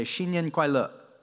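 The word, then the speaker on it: Xin Nian Kuai Le (新年快乐): Say, Xin Nian Kuai Le.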